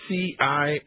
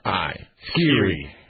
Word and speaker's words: SCIRI: It's SCIRI. – SCIRI.